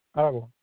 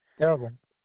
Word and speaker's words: Aragon: Marcos Aragon. Aragon.